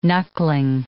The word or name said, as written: knuckling